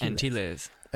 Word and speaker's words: Antilles: Antilles. Antilles.